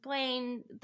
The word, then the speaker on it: Blayne: it's like, it rhymes, you know, Lane rhymes with Blayne.